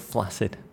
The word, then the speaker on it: flaccid: I don't like the word kudos, but I love the word flaccid. – Yeah. It's just a strange flaccid, the two C's that's become a soft S.